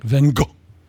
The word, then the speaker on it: Van Gogh: I was going to quote Van Gogh, but I found out you pronounce it like Van Gogh.